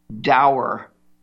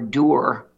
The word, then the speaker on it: dour: You know, we've been entertained by some of this stuff, but I've been pretty rather dour or doer, as you want to pronounce it, because this isn't funny, what we're facing.